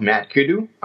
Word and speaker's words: Madkudu: They're called Madkudu. It's spelled M-A-D-K-U-D-U.